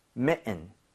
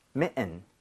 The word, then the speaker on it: mitten: I의 강세를 많이 줘서 사실 들을 때는 mitten, 은처럼 들릴 확률이 높아요.